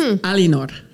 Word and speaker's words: Eleanor: And it was funny because I was talking to my French niece yesterday on the phone, and she told me her girlfriend's name is Eleanor, which is pronounced Alinor.